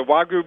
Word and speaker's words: Wagyu: Is it called the Wagyu burger? – Yeah, the Wagyu burger.